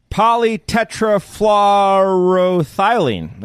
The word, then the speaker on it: Polytetrafluoroethylene: Polytetrafluoroethylene is a hard word to say. It's also better known as... – It sounded like you pronounced it perfectly, though.